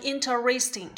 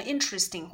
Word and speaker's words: interesting: 好，那么我们刚才听到了，他把这个 interesting 啊读成 interesting，这两个都可以，没有关系的啊。你读成 interesting 或者是 interesting 啊，哪一个都可以啊。